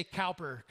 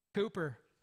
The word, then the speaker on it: Cowper: I was going to say Cowper, because it looked like Cowper. It's C-O-W-P-E-R. Cooper.